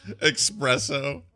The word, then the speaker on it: espresso: There's no X in either of those words. – No. Espresso.